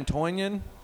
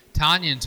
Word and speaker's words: Tonyan: So you got Matt Ryan, Sterling Shepard, Peyton Barber, Juju Smith-Schuster, Ryan Tonyan. Sorry, I mispronounced that. – Like onion? – And Calvin Ridley.